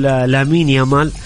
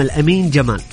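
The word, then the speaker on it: Lamine Yamal: ترى هو اسمه الأمين جمال.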